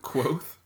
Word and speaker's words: Kvothe: Wasn't it like Quoth? – Yes. – Like Quoth the Raven?